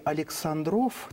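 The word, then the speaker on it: Aleksandrów: На пограничной русско-прусской станции в местечке Александров, такое ударение, на границе царства Польского и Пруссии, встретились два императора, Александр II и Вильгельм I.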